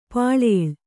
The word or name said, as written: ಪಲ್ಲಿಲಿ